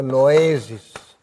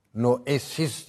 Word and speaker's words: noesis: Bunun üzerine teemmül, gene Eflatun'da noesis, noesis diye yazıyoruz ama bu s iki sesin arasına geldi mi z diye okunur Yunanca'da.